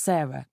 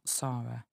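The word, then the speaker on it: Sara: Hello, my name is Sarah. My name doesn't have an H on it, but it's S-A-R-A, but it's pronounced Sarah, not Sarah, so you know.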